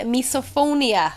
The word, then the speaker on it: misophonia: So there was an awful lot, but it's a thing, misophonia.